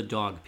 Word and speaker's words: Doeg: Yeah, in the records, that's how it's D-O-E-G, but pronounced dog, named after Dog Island.